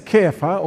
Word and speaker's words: Kepha: The Aramaic is Kepha, or it's sometimes pronounced Cephas. C-E-P-H-A-S.